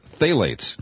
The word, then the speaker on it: phthalates: and that these contain a chemical called it's hard to pronounce because there's a PTH on, PHTH on the beginning of it, phthalates, I think is the way it's pronounced.